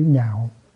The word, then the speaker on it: nhạo: Cái lạc này mình phải đọc là chữ nhạo.